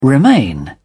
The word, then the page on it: remain: British English